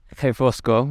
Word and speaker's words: Refosco: I tried to have a Mauro Refosco teach me little things here and there, and he taught me that his name is pronounced Refosco, which is, you know, spelled with an R.